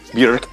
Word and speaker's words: Björk: I hope you swallowed that swig of Mimo, because it's pronounced Björk. – Björk. – Yeah. – Oh. – She just doesn't correct people.